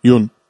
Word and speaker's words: Yuan: I can't pronounce it. – Yeah. Y-U-A-N? – Y-U-A-N. – Yeah. Yun.